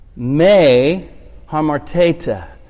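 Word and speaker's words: Me harmar tete (μὴ ἁμάρτητε): Me harmar tete. Teta is actually the right pronunciation. Me harmar tete.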